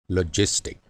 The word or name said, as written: लॉजिस्टिक